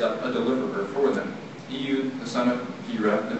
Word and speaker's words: Ehud: So, he sends, and I pronounce it Eud. I looked up how to pronounce it, because I always thought it was Eud. There was one that said Ehud, and then one was Eud. And so, I'm going to say Eud, but E-H-U.